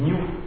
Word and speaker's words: GNU: Donc, voilà son truc, sachant que GNU, ça se présente, ça se prononce aussi new, en vérité, en anglais, donc ce qui veut dire aussi en même temps nouveau.